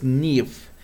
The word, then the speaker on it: Neave: El siguiente sitio es una especie de periscope macabro, este sitio es Nief. Se escribe N-E-A-V-E.